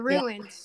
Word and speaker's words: ruins: yeah, the rooms exactly. We, there was a neighborhood of these the rooms. Uh, also, this is another another word I have difficulty to pronounce. Rooms, rooms. – Ruins. Yeah. Ruins. Ruins.